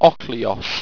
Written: ocleos

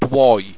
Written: dwoi

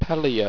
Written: peiliut

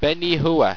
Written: benehua

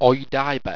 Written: oidibe

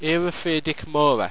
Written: eropheardicmora

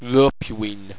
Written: ropuine

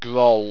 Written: groll

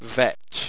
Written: vech

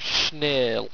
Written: schnail

